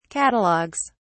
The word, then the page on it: catalogues: British English